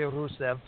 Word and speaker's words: Rusev: And I don't know why I suddenly launch into a Russian accent when I say Rusev. – And, but you're supposed to, because you're supposed to roll your R.